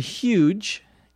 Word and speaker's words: huge: Well, I went to a huge store, and huge, H-U-G-E, some people pronounce that huge with the H almost not even being pronounced.